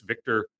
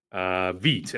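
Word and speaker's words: Vít: They did release Victor Krejci. The former Atlanta Hawk, Veet, actually, is the pronunciation.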